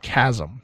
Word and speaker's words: Cosm: So if you haven't seen this, it's spelled COSM. It's pronounced Chasm, CAHSM.